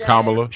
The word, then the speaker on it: Kamala: So it's like a comma. It's that's how she said, that's what she says. – Kamala. – She said it's comma, it's like comma with love. It's Kamala.